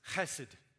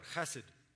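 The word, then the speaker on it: chesed (חסד): And it's the Hebrew word chesed. Okay, you say it like there's something caught in your throat, chesed.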